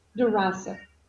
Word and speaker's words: Durazo: Durazo.